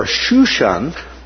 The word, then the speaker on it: Shushan: Susa or Shushan.